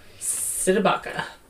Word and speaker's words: Cdebaca: And I was like, I want to say her name right, because her name is literally spelled Cdebaca. Cdebaca. – There you go.